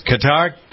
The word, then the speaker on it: Qatar: What's going on in the Middle East, especially the focus on Qatar? How do we pronounce that? Qatar? – Qatar.